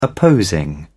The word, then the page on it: opposing: British English